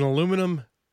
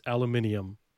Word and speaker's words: aluminium: Well, there it is, Graham Field, the leader of fashion in aluminum tank boxes, or as the people in the UK pronounce, aluminium.